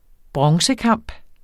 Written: bronzekamp

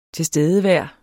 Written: tilstedevær

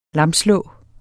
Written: lamslå

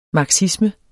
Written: marxisme

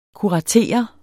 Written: kuratere

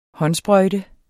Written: håndsprøjte

